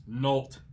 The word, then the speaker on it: Nolte: But this guy insisted that the actor Nick Nolte, that his name is actually pronounced Nolte. He kept referring to him as Nick Nolte, and uh, so he butchered that, and then he butchered uh, the name of the cartoon character Baba Looey.